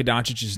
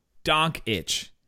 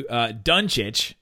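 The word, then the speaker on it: Dončić: All the different ways Marv Albert mispronounced Luka Doncic's name tonight. – How many were there? – Okay, in the first half, he was calling him Donk Itch.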